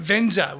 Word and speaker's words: VNZR: Okay, next we have Venza, which is spelt V-N-Z-R, local singer-producer from Goldie, of course, making a bit of soulful pop and R&B.